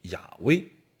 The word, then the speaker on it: 雅辉: 至于这个该怎么发音，本人绝对不是希伯来语的专家，我甚至不是各种，我不是任何一种语言的专家，我连汉语言专家都不是，不是，所以我目前也只能说，这四个的原始发音应该叫做雅辉。这就为什么现在有一些圣经不再说耶和华了，他说的是亚威，